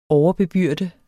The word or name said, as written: overbebyrde